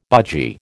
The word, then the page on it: budgie: American English